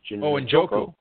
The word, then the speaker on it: Njoku: When drafters see some of these rookies, Howard, Evan Engram with the Giants, how do you pronounce the kid with the Browns, David Njoku? – Njoku, sure, yeah.